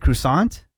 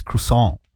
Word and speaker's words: croissant: Is it croissant? – Croissant. – Oh. Well, but that's not the proper pronunciation.